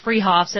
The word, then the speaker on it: Fruhoff's: Spelled Fruhoff's, pronounced Freehoff's, at 33rd and Arapahoe in Boulder.